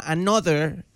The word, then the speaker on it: Anotr: Ey, yo le decía a Gaby, se llama Another. Este muchacho se escribe A-N-O-T-R.